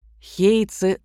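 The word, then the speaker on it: хей (平): Хейцы захватили почти всех генцев. Иероглиф, которым пишется фамилия Тайра, читается еще по-другому – «хей», а иероглиф Минамото – «ген».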